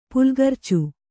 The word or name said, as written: ಪುಲ್ಗರ್ಚು